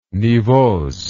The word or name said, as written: nivose